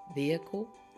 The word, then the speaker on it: vehicle: All right, and some people say vehicle, and you don't hear the H, vehicle, it's usually silent.